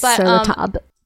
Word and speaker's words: Sur La Table: It's spelled Sur La Table. – It's Sur La Table. – I know that's how it's pronounced, but I don't know why it's spelled table. – Because it's French. – Do they really pronounce table just tab? – Mm-hmm.